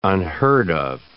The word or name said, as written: unheard-of